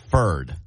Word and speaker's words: Ford: In Maine, you would pronounce him as Ferd. – Yes. – Yes, he was very clumsy, right? Yeah, yes. – Ford.